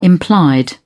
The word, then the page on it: implied: British English